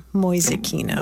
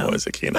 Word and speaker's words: Mäusekino: How do you spell that? Mäusekino. – M-A-U-M-L-A-U-S-E-K-I-N-O, Moisekino. – Moisekino.